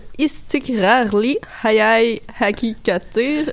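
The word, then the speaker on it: İstikrarlı Hayal Hakikattir: Euh, donc c'était une chanteuse turque, Gay Su Akyol qui euh, nous a présenté son nouvel album Istikrarli Hayai Hakikatir. Désolé si je Je pense que tu l'as bien prononcé, puis on te pardonnera.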